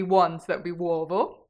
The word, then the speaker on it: uovo: Uovo.